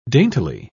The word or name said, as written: daintily